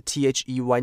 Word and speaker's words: they: 我顺便讲一下，好像呃，我刚刚念这个 they 这个字，那你要注记得，只要有看到 th 的时候，they 是 they，你看到 th 的时候要咬住舌头，不然你会念成很累的累。